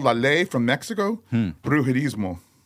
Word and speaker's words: Brujerizmo: Brujerismo? – Yes. – Brujerismo.